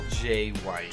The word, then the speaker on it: Jai: But anyway, So Tyson, Michael Jai White, how you say it? Jai White? – Michael J. White. – J. White, okay. – Yeah, it's weird how it's spelled, but it's just.